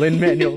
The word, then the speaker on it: Lin-Manuel: I'm saying it wrong again, Lin-Manuel Miranda's name,